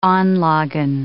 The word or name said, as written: anlagen